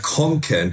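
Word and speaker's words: Kånken: Konken. Da ist so ein A, da ist so ein Konken. – So ein Runterkreis über dem A, das heißt O, das sozusagen. Konken.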